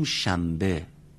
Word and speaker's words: شنبه: ما تو فارسی هم اینطوری میگیم، شنبه رو میگیم شنبه، پنبه رو میگیم پنبه، در برای اینکه راحت تر بشه تلفظ اینطوری تلفظ میکنیم.